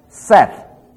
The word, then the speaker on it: saith: S-A-I-D is pronounced said. S-A-I-T-H is pronounced set. See how similar they are? One is spelt with a D and the other with a T-H.